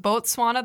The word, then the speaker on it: Botswana: It's pronounced Botswana, the country, but the people there are called Botswana. So, I mean, you're technically right.